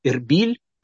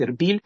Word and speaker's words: Эрбиль: Есть такой город, называется он Арбела или Эрбиль, так называется он, наверное, на современном языке, такое произношение сейчас, Эрбиль.